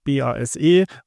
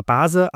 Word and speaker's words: BASE: Ich hätte immer gedacht, man sagt BASE oder Base, aber es wird sehr englisch BASE ausgesprochen.